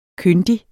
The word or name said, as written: kyndig